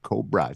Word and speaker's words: Cobrah: This is Mommy by Chris Lorenzo and Cobra, C-O-B-R-A-H. – Okay. – The H is silent.